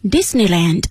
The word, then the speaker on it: Disneyland: Disneyland 通常呢，就是这个如果 land 它作为这个名词的后缀时呀，我们既可以读作 land 也可以读作 land。 Disneyland Disneyland 都可以，迪士尼乐园。